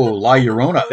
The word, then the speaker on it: La Llorona: We've got some, and I always butcher this name, our Spanish weeping woman. – Oh, La Llorona.